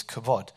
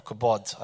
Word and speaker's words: kabod: And that word glory in, there, in Hebrew is kavod or kabod. I, I can never pronounce it properly because I'm not Hebrew. So it's kabod.